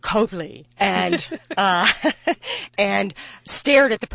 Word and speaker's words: Copley: But similarly, you don't know how to pronounce the word C-O-P-L-E-Y, because in Boston, that is Copley. That is not Copley.